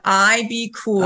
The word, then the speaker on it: IBKUL: Yeah, it's IBKUL. It's called IB Cool.